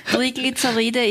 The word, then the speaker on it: Triglyceride: Was sind Triglyceride? Habe ich das richtig ausgesprochen? – Ja, Triglyceride ist auch eine bestimmte Sorte von Blutfetten.